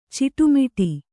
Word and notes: ಚಿಟ್ಟೆಮುಟ್ಟಿ: kannada

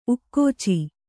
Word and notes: ಉಕ್ಕಾಚು: Kannada; ukkācu

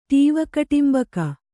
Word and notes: ಟೀವಕಟೆಂಬಕ: Kannada

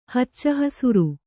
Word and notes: ಹಚ್ಚಹಸುರು: kannada

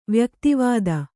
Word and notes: ವ್ಯಕ್ತಿವಾದ: vyakti vāda

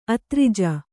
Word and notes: ಅದ್ರಿಜೆ: adrije

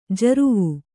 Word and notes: ಜರ್ವು: jarvu

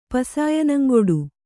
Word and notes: ಪಸಾಯನಂಗೊಡು: kannada

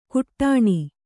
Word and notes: ಕುಟ್ಟಣಿ: kannada

